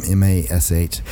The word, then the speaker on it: Mash: MASH. Why do people misspell my name? Mash. Isn't that simple? – Yeah, it sounds easy.